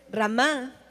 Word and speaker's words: Ramá: Entonces, Ramá, con acento en la A, significa angustia, llanto de una madre por sus hijos.